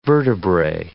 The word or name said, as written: vertebrae